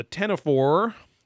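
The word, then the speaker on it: ctenophore: They talk about the tenophore, which is the cone jelly, which is like the Wolverine. It has a C in front of the T, which is very weird. They don't pronounce the C.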